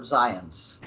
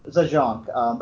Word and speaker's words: Zajonc: So yeah, I remember looking back through my notes after one lecture and seeing that I'd written the words rhymed with science and it took me. And then I was like, oh, right. I wrote down how to pronounce Robert Zients. I definitely would have said Zajonc,